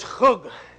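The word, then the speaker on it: chug (חוג): But the Hebrew word is chug. If you were to transliterate it, it'd be K-H-U-G. It's chug.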